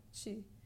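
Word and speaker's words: ti: So for example, in the second line, sinceramente, and for example, the word T-I, chi, it means it's a form of you.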